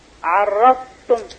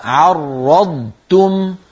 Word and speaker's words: عرضتم: عرضتم لا الضاد ثم تاء. عرضتم لا المهم المهم ألا تكون طاء.